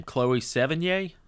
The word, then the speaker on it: Sevigny: Chloe Sevigny. Is that how you pronounce her name? – Sure.